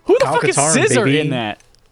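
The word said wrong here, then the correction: Xizor: So, for context, I believe it is pronounced Caesar.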